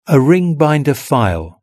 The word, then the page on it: a ring binder file: British English